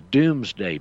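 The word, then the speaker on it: Domesday: Again, go to Black's Law Dictionary and look under a listing called the Doomsday Book. It's spelled like dome, but it's called Doomsday, you know, like how Dr. Strangelove and How I Learned to Love the Bomb.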